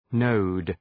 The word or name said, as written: node